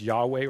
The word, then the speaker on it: Yahweh: We pronounce it as Yahweh or Jehovah.